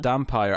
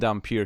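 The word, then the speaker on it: Dampyr: Dampire. I'm saying Dampier because it's spelt with the Y like vampire.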